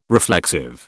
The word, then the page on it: reflexive: British English